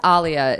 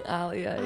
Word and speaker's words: Alia: Well, it's pronounced Alia. It's actually just easier for most white people to say Alia. – Alia. – Alia, yeah.